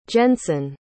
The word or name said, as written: jensen